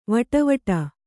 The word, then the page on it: ವಡವಡ: kannada; vaḍa vaḍa